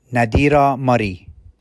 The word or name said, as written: Nadira Murray